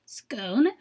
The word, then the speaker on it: Scone: It looks like scone, as in the delicious snack, but it is actually pronounced Scone.